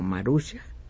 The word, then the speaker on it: Maruja: Esa es una pequeñísima, pequeñísima muestra de la obra de que me dice Oscar que lo correcto es pronunciar su nombre como Maruja.